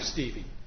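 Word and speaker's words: Stueve: It's Patrick Stevie. It's a tricky German name. – UE is pronounced E in German, isn't it? – Yes. – Steve. – Yeah, well, you know, actually, through the Internet, we found out that the Germans, because it's spelled with an umlaut, it's pronounced Stuva.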